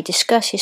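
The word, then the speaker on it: Disqus: By the way, Discuss is spelled D-I-S-Q-U-S. I think that's how you pronounce it anyway.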